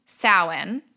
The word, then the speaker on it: Samhain: Okay, so the only other thing that I kind of came across in my research was that similar to Samhain, am I saying that correctly, Jackanovia? – Yeah, that's right.